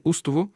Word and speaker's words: Устово: И сега все още ще чуете старите хора от Устово и околията му да казват Остово с първа гласна О и ударение върху втората гласна О, а не Устово, както се произнася сега името на това село.